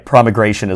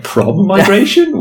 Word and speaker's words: promigration: I thought of putting in an extra M there to make the pronunciation clear, but then it's like prom-migration? Why?